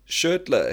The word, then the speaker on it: Schertler: How long you been using Schertler, hey? Am I saying it correctly, the brand? – Yeah, Swiss.